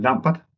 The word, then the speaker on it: Lambert: Bare Lambert. – Lambert? – Ja. Yeah. – Ligesom altså, i Slambert? – Ja, lige præcis.